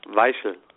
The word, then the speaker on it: Weichel: Weichel.